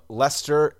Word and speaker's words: Leicester: And I'm going to read Luke from Leicester, England. I think that's how I was told to pronounce it on Twitter. To me, it looks like Lancaster.